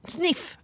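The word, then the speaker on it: Sniph: Och jag och Sanna var på ett event nyligen med Snip. Sniff, ursäkta. Sniff. Sniff. Det stavas Snip och ett H på slutet. Men det uttalas ju Sniff.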